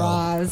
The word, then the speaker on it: Ra's: If we do go back to his Arabic roots, I believe it's pronounced Ra's. There we go. – Ra's.